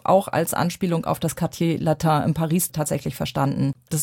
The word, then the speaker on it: Quartier Latin: Also ich würde sagen, das ist schon das Latin Quartieret. Habe ich es jetzt richtig ausgesprochen? – Das Quartier Latin.